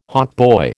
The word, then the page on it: hautboy: American English